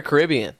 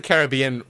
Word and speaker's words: Caribbean: So, is it Caribbean or Caribbean? – Okay, so the place is pronounced Caribbean, but Pirates is always Pirates of the Caribbean. I don't know why.